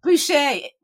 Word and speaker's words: Bouschet: Bouschet.